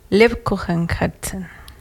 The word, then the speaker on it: Lebkuchenherzen: Lebkuchenherzen. ¿Lo pronuncié bien? – Ah. Sí.